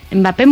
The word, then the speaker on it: Mbappé: Mbappé muy bien, ¿eh? – Mbappé hot.